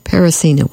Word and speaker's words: Peresina: And there was also another participant, but not on that particular tune, which was Parasina. P-E-R-E-S-I-N-A. Parasina, we'll go with that pronunciation.